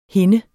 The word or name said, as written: hinde